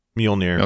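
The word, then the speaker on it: Mjölnir: Captain America finally wields, oh, how do you say it? – Mjolnir. – Okay. Mjolnir? – Or meow meow.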